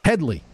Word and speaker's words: Headley: His last name rhymes with Schmedley. – Medley? – Headley.